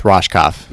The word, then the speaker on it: Rohskopf: Am I pronouncing your last name right? – It's Rohskopf. – Rosh. – Yeah. – Like an S-H. Rohskopf. Yes.